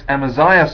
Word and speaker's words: Amaziah: Well, Amos speaks for the establishment, the institution, and Amos speaks for God. I don't know, did I say that right? Amaziah speaks for the establishment, sorry. Amos speaks for God.